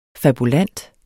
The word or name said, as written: fabulant